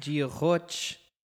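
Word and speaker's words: Dia dhuit: And it's weird because it's spelled like not like Giochoch, but that's how you say it.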